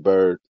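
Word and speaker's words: Byrd: My real name, Calvin Bird, spelled with a Y, B-Y-R-D.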